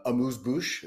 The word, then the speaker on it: amuse-bouche: And it's sort of that amuse-bouche.